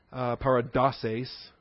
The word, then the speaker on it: paradoses: In the plural, it becomes paradoses. Accent slides one syllable because the final syllable is lengthened from sis to sace.